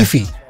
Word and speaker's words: Keffe: Keefe. – K-E-F-F-E. – Yeah, no, I thought it was Keefe. – Oh, this whole time. – I heard someone say it in a podcast, Keefe D.